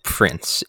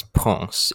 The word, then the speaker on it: Parc des Princes: Yes, I was gonna say that you guys are technically wrong because it isn't Prince, it's Ponce, it's Parc des Princes.